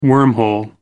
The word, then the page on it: wormhole: American English